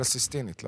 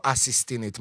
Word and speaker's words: סיסטינית: קפלה סיסטינית, לא? – קפלה הסיסטינית? – לא אסיסטינית, מה זה, אסיסטנטית? – סיסטין צ'אפל. – כן, קפלה סיסטינית.